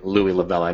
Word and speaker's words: Louie Lavella: Yeah, and all my accounts, including the website, is my first and last name, Louie Lavella.